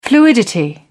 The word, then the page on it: fluidity: British English